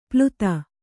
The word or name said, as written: ಪ್ಲುತ